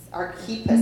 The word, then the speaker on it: Archippus: Was this Archippus? Is that how you say it? Was he just a Colossian? Or a Let's see. At the very end. – Yeah, 17. – Archippus. Archippus is what my guy on YouTube told me last night.